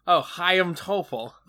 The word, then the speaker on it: Chaim Topol: Oh, Chaim Topol.